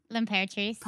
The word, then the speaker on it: L'Imperatrice: Okay, if you want to say it with like the accent, it'd be like L'Imperatrice, but you could just say L'Imperatrice.